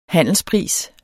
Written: handelspris

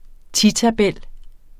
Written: titabel

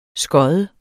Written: skod

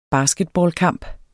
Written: basketballkamp

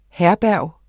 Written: HERBERGERNES

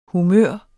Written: humør

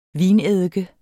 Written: vineddike

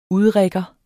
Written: udrigger

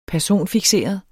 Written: personfikseret

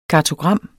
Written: kartogram

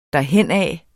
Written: derhenad

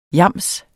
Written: yams

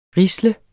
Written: risle

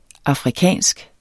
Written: AFRIKANSK

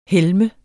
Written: helme

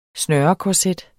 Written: snørekorset

